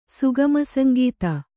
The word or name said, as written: ಸುಗಮಸಂಗೀತ